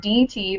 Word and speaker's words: Marquardt: My new last name is Marquardt, which is not an easy name either. Yes. So it's Marquardt. There's a silent D in there.